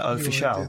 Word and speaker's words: Official: Official.